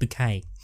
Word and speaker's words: Bucket: Does it say the word bucket but pronounce it bouquet? – Absolutely.